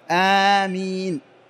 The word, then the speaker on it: آمين: دعاء اللهم استجب، آمين يعني استجب يا الله أو اللهم استجب، وهكذا تنطق آمين، وليس آمين ولا أمين، بل آمين يعني استجب اللهم استجب. بعض الناس يقول آمين وهذا غلط، آمين يعني قاصدين، يعني أم الشيء يعني قصده، فيختلف المعنى، أو يقول أمين، أمين غير آمين. فالنطق الصحيح لها هي آمين،